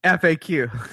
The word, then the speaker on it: FAQ: How do you pronounce it? – FAQ. – No, whatever. I say fact. – I know, but I used to too. – Yeah. FAQ. It's frequently asked questions.